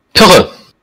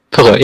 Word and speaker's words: Pirre: How you say your name? Say it for us. – Pirre. – Pirre? – Pirre. Yeah, that's close. That's good. But I know that it's tough for you guys to pronounce, so. – No, I can, bro. You don't know, man. I can say things. Pirre? – Pirre, yeah. Perfect.